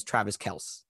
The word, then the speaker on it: Travis Kelce: Travis – okay, guys, for those who don't know, Travis Kelce has informed the world that the correct pronunciation of his name is Travis Kelce.